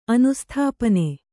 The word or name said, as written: ಅನುಸ್ಥಾಪನೆ